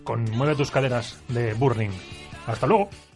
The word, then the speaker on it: Burning: ¿De Burning? ¿Cómo se dice? – Burning. – Burning, pero es Burning. – Sí, pero son madrileños, es Burning.